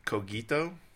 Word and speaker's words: cogito: Is it cogito? – Yeah, yeah. Hard G's. Oh. Hard G's.